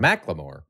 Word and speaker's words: Macklemore: Macklemore. – Macklemore and Lewis. – Macklemore. M-A-C-K? M-A-C? – It's spelled M-A-C-K-L-E-M-O-R-E. So it's Macklemore.